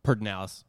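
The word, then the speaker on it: Pedernales: Is it Perdinales? Am I saying that right? – Perdinales. – Perdinales? Okay, yeah, Perdinales. We took him in that, and it was fun.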